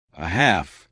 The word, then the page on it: a half: American English